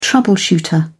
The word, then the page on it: troubleshooter: British English